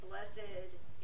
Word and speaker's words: blessed: Blessed is an adjective meaning holy, in which case it's pronounced with two syllables.